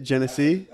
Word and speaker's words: Genesee: How do you say it? Genesee? – That's how you pronounce it.